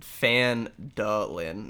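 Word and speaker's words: Phandalin: Yeah, it's Fandalin, not how I've been saying it.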